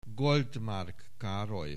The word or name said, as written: Goldmark Karoly